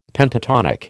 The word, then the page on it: Pentatonic: American English